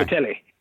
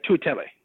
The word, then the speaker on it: Tutele: I hopefully pronounced his name right. – No, you didn't. Yeah. Tutele. It might not have been spelled right either, but yeah, Tutele. So the question is, three out of those guys.